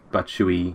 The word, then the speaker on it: Batshuayi: I mean, Chelsea have got Kante in, who looks really good, and they've got Batshuayi, whose name I can never pronounce properly, like Batshuayi. – I think that's correct.